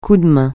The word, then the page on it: coup de main: French